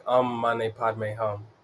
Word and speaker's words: Om Mani Padme Hum: There's this mantra in Buddhism that's, uh, you say it like, Om Mani Padme Hum um Mane Padme Hum means the jewel is in the lotus.